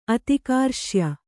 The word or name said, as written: ಅತಿಕಾರ್ಶ್ಯ